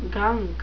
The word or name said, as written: gunk